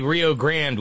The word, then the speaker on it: Rio Grande: And we say Rio Grande with grand as one syllable because we're basically crackers.